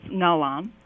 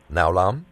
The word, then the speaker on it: Ngao Lam: Is it Ngao Lam? – It's Ngao Lam. – Ngao Lam? – Yes.